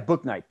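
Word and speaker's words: Bouknight: James Bonight or whatever? – Yeah, Booknight. – Whatever, however you pronounce it. – Yeah, James Booknight.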